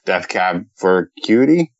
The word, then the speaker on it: Death Cab for Cutie: But it wasn't enough for me to go listen to more Death Cab for Cutie.